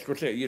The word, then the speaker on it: Scorsese: This is not a criticism that how do you pronounce it here? Scorsese, Scorsese. Yes, Scorsese, it reminds how Italian or American, but that the Taxi Driver is basically a remake of Searchers, no, basically.